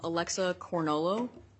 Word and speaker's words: Alexa Cornolo: Alexa Cornolo. No worries, I'm mispronouncing. It's Italian.